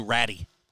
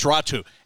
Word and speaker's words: Raty: The ones that said Artur Ratty. – Yeah. – It's because his name is R-A-T-Y, but it's Ratu.